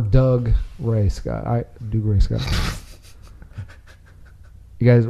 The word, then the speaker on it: Dougray: It's literally Doug Ray. – Dougray. – As one word. – Yeah. – Oh, no. – It's Doug Ray.